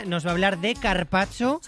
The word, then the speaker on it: carpaccio: Que te has perdido una letra. Carpaccio.